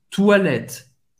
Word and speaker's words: toilettes: o T et mudo, les, les, toilette, toilette, acaba no T, na pronúncia, toilette, banheiro, em francês, toilette, sempre no plural, ok? mais na pronúncia, você pode, pode ver, acaba aqui no toilette, o E é bem discreto, até mudo et o S não se pronuncia.